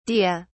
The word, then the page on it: dea: British English